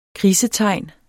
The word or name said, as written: krisetegn